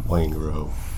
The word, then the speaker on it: Waingro: Is that how you pronounce it? Wangro? – Wangro.